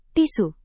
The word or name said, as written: tisu